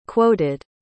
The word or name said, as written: quoted